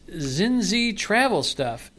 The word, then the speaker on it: Zinzi Travel Stuff: And I'm pronouncing it Zinzi Travel Stuff.